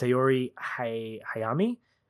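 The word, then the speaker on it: Saori Hayami: By the way, I think her name is pronounced Sayori Hayami.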